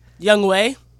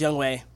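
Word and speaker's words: Younghoe: My man Young Ho from the Falcons, he got three. – Young Wei. – Oh. Am I pronouncing it wrong? – Yeah, it's pronounced Young Wei.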